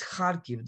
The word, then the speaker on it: Kharkiv: And everyone says Kharkiv, Kharkiv, and that's just wrong. It's Kharkiv. The accent is on the first syllable.